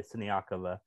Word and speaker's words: Siniakova: If, if Krejcikova and Sineak, I'm so sorry, Siniakova.